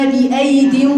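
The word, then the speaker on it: بأييد: والسماء بنيناها بأيد ونحن الموسعون. بأيد لو بصيتي في المصحف هتلاقي ايه؟ ياء عليها ايه؟ دايرة. هتقولي لي طب انت ما نطقتيش الياء التانية دي، يقولك لا اصلها لا تنطق.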